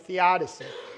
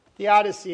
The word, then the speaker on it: theodicy: It falls under the heading of theodicy. Theodicy, it's spelled T-H-E-O-D-I-C-Y. Theodicy.